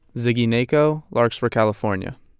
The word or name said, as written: Ziggy Nacco